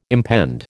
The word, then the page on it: impend: American English